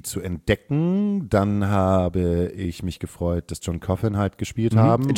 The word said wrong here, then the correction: John Coffey: John Coffey im Übrigen. – John Coffey. Sorry. Ich habe mir gestern gesagt, das wird John Coffin ausgesprochen. – Auf keinen Fall, John Coffey.